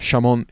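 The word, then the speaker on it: xamón: So when, if ever, you're in Galicia and you see an X, so we have Chamón instead of Jamón, the X is generally pronounced like a sh in English.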